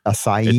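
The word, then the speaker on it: açaí: You know, not all the acai places are. This one was. – Acai. Like, acai. – It took me a while to figure out how to pronounce that. I used to say acai.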